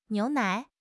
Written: niu2 nai3

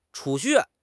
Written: 储蓄

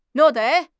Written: 虐待

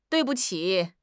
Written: dui4 bu5 qi3

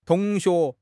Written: tong2 xue2

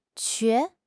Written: 瘸

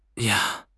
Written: ya1

